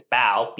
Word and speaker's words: Bao: You know, my last name, it's Bao, B-A-O. But I remember, like, my teacher, like, she called me Wayne Boa, which, like, makes no sense, right?